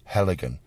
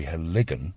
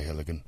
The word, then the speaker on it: Heligan: So they put the stress on the first syllable, and they'll say Heligan. Of course, it's wrong. It should be Heligan. But the problem is that it's spoken that way so much over the media that people sort of assume that Heligan is correct, and it isn't.